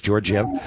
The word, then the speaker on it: Georgiev: Now, this Georgiev, is that how you pronounce that guy's Georgiev? – Yeah, in um, Colorado.